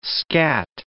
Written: scat

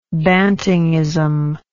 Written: bantingism

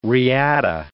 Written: riata

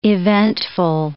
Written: eventful

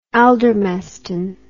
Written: aldermaston

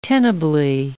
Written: tenably

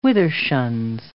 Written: withershins

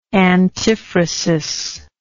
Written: antiphrastically

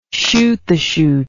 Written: chute the chute

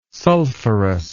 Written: sulphurous

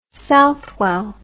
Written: Southwell

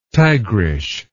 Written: tigrish

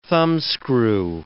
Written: thumbscrew

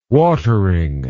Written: watering

